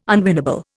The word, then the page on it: unwinnable: Indian